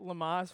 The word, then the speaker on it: Lamaze: I called it Lamaze, like it was French or something. It's Lamaz, and this is an octopus, and I just want to say this is an amazing toy.